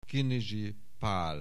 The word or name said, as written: Kinizsi Pál